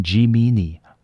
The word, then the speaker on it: Gemini: 双子座也是非常容易念错的发音错误的星座，它的发音是 Gemini，Gemini，好，因为它是拉丁文转过拉丁文时代就有的字，因为它非常容易念成 Gemini，好，但是它是 Gemini。